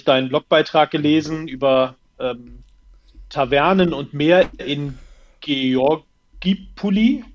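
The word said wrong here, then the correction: Georgioupoli: Wie wird es ausgesprochen? – Georgopoli.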